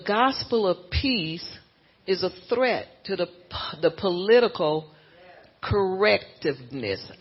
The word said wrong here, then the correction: political correctness: I know I said that wrong. Let me say that right. Political correctness threatens our speech, our free speech, and it threatens our peace.